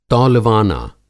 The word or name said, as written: तौलवाना